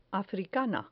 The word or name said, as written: africana